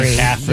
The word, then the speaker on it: McCaffrey: McCaffrey. Yes. – How do you say his name? – McCaffrey. – McCaffrey. Yeah, Christian McCaffrey.